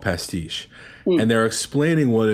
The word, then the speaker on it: pastiche: Pastiche? – Pastiche, it's a French word. P-A-S-T-I-C-H-E, I think is how it's spelled.